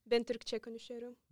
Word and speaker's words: Ben Türkçe konuşuyorum: And how do you say that in Turkish? – Ben Türkçe konuşuyorum. – Ben Türkçe konuşuyorum.